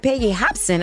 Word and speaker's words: Hobson: The fourth Monday of the month is going down at a Peggy Hopkins. Is that, am I pronouncing it right? Peggy Hobson, okay.